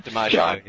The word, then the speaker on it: DiMaggio: DiMaggio, yeah.